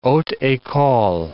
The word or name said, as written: haute ecole